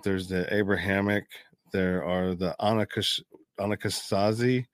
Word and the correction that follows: Ashkenazi: Ashkenazi. – I can never pronounce that. Ashkenazi.